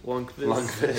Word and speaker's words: Lundqvist: Lundqvist.